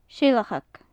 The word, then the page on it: seilcheag: gaelic